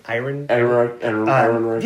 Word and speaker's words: Ehrenreich: And to your point about Ehrenreich, am I saying that right? – Ehrenreich.